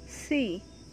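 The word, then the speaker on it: Si (呬): Si, pronounced like sir, with a slow exhale, which is associated with the lungs.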